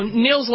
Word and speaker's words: Nils: I don't know how to say his first name, but Lofgren. – Oh, Nils Lofgren, Nils? – Yeah. – Nils again, Lofgren, yeah.